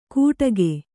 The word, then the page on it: ಗುಡಾಕೆ: Kannada